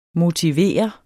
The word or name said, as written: MOTIVERER